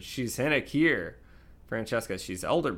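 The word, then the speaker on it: Hen Ichaer: How do you say that? Hin Akir? Is that how I say it? H-E-N-I-K-I-E-R? – Yes. Yep.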